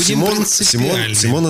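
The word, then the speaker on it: Simone: Но Симон это точно, потому что в свое время вот этот псевдоним Нина Симон взяла в честь французской актрисы, понимаешь? Поэтому он произносится именно вот так вот.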